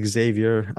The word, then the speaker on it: Xavier: Is it Javier or Xavier Jens? – I'm going to say Xavier. Jens. Yeah.